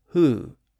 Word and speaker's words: who: So when we use the phrase who, what, when, where, and why, they're all spelled with W-H, and they're all pronounced with a W sound except the word who.